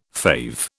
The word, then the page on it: fave: British English